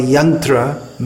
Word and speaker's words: Yantra: Srila Prabhupada would say Jantrarud, because in Bengali, Yantra, machine, is pronounced Jantra.